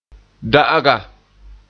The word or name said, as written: da'aga